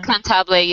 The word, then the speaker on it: contable: Contable, yes.